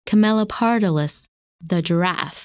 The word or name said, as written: Camelopardalis